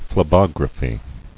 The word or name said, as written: Phlebogram